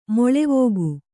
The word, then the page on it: ಮಲವೇಗ: mala vēga